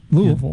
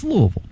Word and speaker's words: Louisville: Yeah, and you said it correctly. Louisville. Yeah, that's right. It's Louisville.